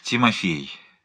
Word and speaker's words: Тимофей: Второй слог произносится как «промах», ударение на последнем слоге, «ей», как в «прей» добыча, но чуть более растянуто, «Тимофей Палыч Пнин», что значит «Тимоти, сын Поля».